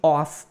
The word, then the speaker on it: off: Ele fala exatamente isso na música, I can get her off my mind. E lembrando que esse off aqui é uma pronúncia com F mesmo. Off não é aquele of de, né? De alguma coisa.